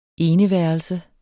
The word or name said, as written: eneværelse